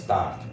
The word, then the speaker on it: Thoth: I don't know if you know the proper way to say his name is Thoth, but I'm super familiar with Egyptian.